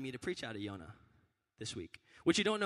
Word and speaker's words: Yonah: Yonah is the Hebrew pronunciation of Jonah.